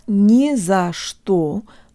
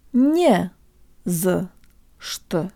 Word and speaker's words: не за что: It's not не за что, but rather jo tang lips не за что.